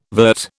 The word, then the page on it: vert: British English